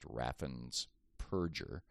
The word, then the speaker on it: Raffensperger: He won by 50,000 votes, and then repeated his efforts again this year through his Secretary of State, Brad Raffensperger, whose name, ironically, is correctly pronounced Raffensperger,